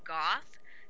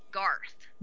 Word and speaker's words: Goth: I honestly did not, I could not put it through my brain that they actually named the character Goth, so I kept hearing Garth. Garth, until someone later in the episode actually really, you know, slowly pronounced it. I'm like, Goth.